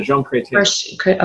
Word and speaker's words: Jean Chrétien: Chrétien, Jean Chrétien.